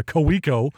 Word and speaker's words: Kaweco: But what I'm currently rocking is the Kaweco. And if you're an un, familiar to the company. You would pronounce it in America, Coeco.